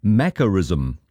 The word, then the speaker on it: macarism: And to the word now, this morning it is Macarism. Let me spell it, M-A-C-A-R-I-S-M.